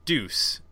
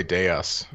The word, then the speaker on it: dEUS: It's, I'm going to say it's deuce. Is that how you say it, Jay? D, lowercase d, uppercase E-U-S. Is that right? – I was going to say dEUS. – dEUS? Oh, okay. dEUS, like dEUS Machina.